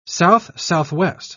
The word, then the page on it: south-southwest: British English